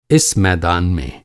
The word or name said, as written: मैदान